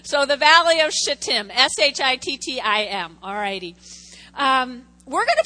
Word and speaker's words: shittim: Accent, I'm going to say the word, shittim. S-H-I-T-T-I-M.